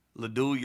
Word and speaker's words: Ladue: So I was at Ladue, and our conference, I think, was Eureka, Ladue, Eureka, UCity, Clayton. – You said it right. All of them.